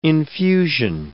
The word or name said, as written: infusion